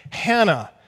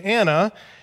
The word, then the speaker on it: Hannah: The Greek text actually uses a rough breathing mark before the A, so we should pronounce her name Hannah with the H.